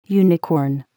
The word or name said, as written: unicorn